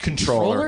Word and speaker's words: Comptroller: Did you know it's pronounced controller? – It's pronounced controller.